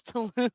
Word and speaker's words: Tulum: The last event was on Thursday, and it was Teresa's event, and she called it, like, Tipsy and Tulum. – It's Tulum. Like, the way you get, like, every city or country wrong, and I told you last time you said it wrong.